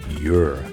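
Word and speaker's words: Yr: Edges might be a great way to describe the trajectory of Steve Tibbetts' music since his 1980 DIY masterpiece, Your, that's spelled Y-R.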